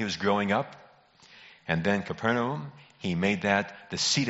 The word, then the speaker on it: Capernaum: Capernaum, and I know you're used to hearing Capernaum, but it's not pronounced Capernaum. It is Capernaum, is in Naphtali.